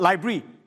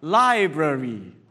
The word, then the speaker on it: library: But the right way to pronounce this is not library, but library.